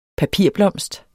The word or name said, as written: papirblomst